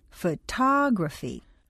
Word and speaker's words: photography: The first word is stressed on the first syllable, so photograph sounds like photograph. The second word is stressed on the second syllable, photography, so the word comes out photography.